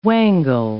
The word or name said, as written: wangle